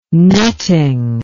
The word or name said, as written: netting